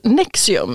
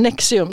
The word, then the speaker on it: NXIVM: Vi har ju också en modern sekt som avslöjar, som heter, det uttalas Nexium, men de skriver med stora bokstäver sin organisation som NXIVM, men Nexium ska det uttalas.